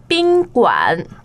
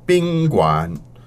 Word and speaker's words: 宾馆: Hotel, how do we say that one? – 宾馆. – And the tones on that, please? – First and third. – First and third. So, 宾馆.